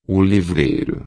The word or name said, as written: livreiro